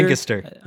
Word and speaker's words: Lancaster: Yeah, I would acknowledge Josh and thank him, but he mispronounced Lancashire. – Lancaster. – I think is what it's called. I'm pretty sure. – All you guys. – So yeah, Lancaster, PA.